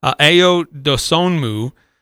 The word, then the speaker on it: Ayo Dosunmu: Yeah, 9-4-6-3 brings up the final one on the draft mock drafts that I found. That's Ayo Dosunmu.